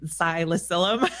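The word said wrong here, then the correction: Ksi Lisims: Xylism. Thank you.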